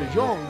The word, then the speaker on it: de Jong: De Jong, eh, de Jong. – Pero se escribe diferente. De Jong, ¿no? – De Jong como de joven.